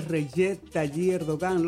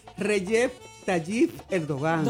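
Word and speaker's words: Recep Tayyip Erdoğan: La primera delegación que se paró fue la de Turquía, que estaba ahí estaba el presidente Recep Tayyip Erdogan. Lo dije bien, ¿eh? – Está bien ahí, hombre. – Pero mire. – Se llama, el presidente de Turquía se llama Recep Tayyip Erdogan.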